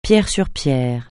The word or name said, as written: pierre sur pierre